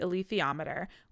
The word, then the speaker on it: alethiometer: So Lyra has consulted with the alethiometer, which I did hear that was the correct pronunciation. – Yes.